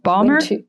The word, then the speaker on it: Balmer: And I hope I'm pronouncing her name correctly, Beth Bomer. Is that the correct spelling? – Balmer? Beth Balmer. – Beth Balmer. Yeah.